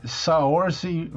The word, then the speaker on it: Saoirse: É Saoirse Ronan, hum.